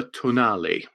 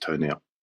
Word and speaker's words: Tonale: I'm going to go for the Alfa Tonale, which is definitely not pronounced toenail.